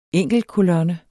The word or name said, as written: enkelte